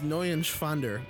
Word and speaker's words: Neuenschwander: It's N-E-U-E-N. It's Neuenschwander. Anthony Neuenschwander.